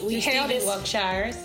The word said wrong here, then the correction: Wiltshire: Stephen Wiltshire.